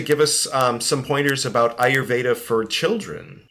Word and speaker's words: Ayurveda: Yeah, Ayurveda.